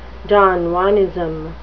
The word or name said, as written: don juanism